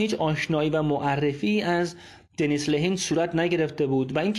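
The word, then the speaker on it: Dennis Lehane: اولین چیزی که میخوام درباره این نویسنده و کتابش بگم اینه که تلفظ صحیح نام نویسنده کتاب جزیره شاتر دنیس لهین هست و نه اونطور که من بعضی جاها دیدم نوشته شده دنیس لیهان دنیس لیهان غلطه و دنیس لهین درسته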